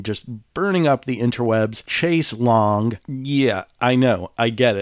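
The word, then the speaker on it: chaise longue: My brain also wants to say and read Chase Lounge, but it's spelled L-O-N-G-U-E. They just flipped a couple letters and changed Chase Lounge into Chase Long, and there's not a goddamn thing I can do about it.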